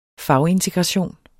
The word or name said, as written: fagintegration